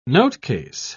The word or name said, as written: notecase